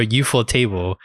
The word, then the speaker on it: ufotable: Everyone always is like, how do you pronounce it? Ufotable or UFO table? It's UFO table.